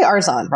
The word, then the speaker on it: Arzon: How do you say her last name? Arzon? – I say Arzon, Robin Arzon.